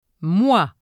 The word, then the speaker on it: moi: OI and OI accent circonflexe are pronounced as WA. – Moi. Boîte.